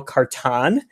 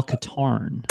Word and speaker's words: Katarn: But I love, like, I only played through the first couple missions recently, but the first mission, you play as Kyle Katarn, who is a character created for... – It's Kyle Katarn. – Katarn. – Kyle Katarn. – Katarn. Okay. Yes.